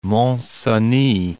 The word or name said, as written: Cenis, Mont